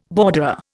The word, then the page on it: borderer: Indian